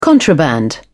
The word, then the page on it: contraband: British English